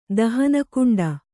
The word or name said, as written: ದಹನಕುಂಡ